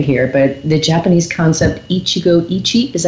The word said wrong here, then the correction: Ichigo ichie: In Japanese, would be Ichigo Ichie.